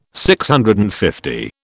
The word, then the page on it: DCL: British English